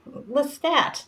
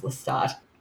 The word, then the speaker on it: Lestat: By the age of 10 or 11, I was like, Lestat. That's how I pronounced it back then, because that's what it looked like on the page. I didn't know it was Lestat.